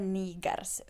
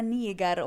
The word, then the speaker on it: Niger: Men, och vi säger man på svenska Niger och inte Niger. Eller Niger.